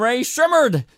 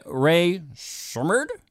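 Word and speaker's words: Rae Sremmurd: Ray Shrimmerd? So for those that don't know, it's drummer's ear spelled backwards.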